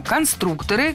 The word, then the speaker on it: конструкторы: Конструктора, конструкторов, это неверно. В слове «конструктор» ударение должно сохраняться на втором слоге, на «у». Поэтому конструкторы, конструкторов.